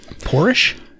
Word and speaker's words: Porsche: I'm going to give you the opportunity to correct that pronunciation. – Porsche? – You could go with Porsche or Porsche, but probably not Porsche. Porsche.